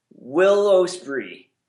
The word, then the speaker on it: Ospreay: Well, let me just say to Mr. Richards, the Renegade 28, Will Ospreay, and there you go, said it correctly.